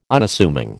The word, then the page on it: Unassuming: American English